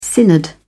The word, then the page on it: synod: British English